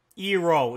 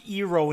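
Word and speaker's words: Iroh: Ero. It's pronounced Ero in this movie.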